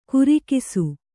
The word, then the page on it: ಕುರಿಕಿಸು: kurikisu